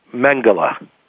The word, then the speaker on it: Mengele: Mengele.